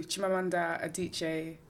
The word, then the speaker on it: Chimamanda Adichie: Chimamanda Adichie.